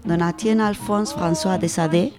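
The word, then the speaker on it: Donatien Alphonse François de Sade: Y también hablaremos del señor, lo voy a pronunciar fatal, tú que sabes francés, Nacho, Don Etienne Alphonse François de Sade. – Perfecto.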